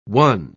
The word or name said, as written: won